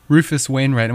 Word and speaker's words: Rufus Wainwright: Rufus Wainwright.